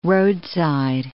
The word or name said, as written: roadside